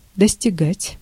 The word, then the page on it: достигать: /dəsʲtʲɪˈɡatʲ/